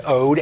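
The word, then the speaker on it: ode (ᾠδή): Hodei, O-D-E, you would pronounce it ode. The Greeks pronounced it ode.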